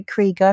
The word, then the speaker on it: Krieger: It's pronounced Robbie Krieger. I think I've been pronouncing wrong.